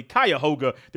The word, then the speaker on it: Cuyahoga: Cuyahoga, there you go, Falls High School.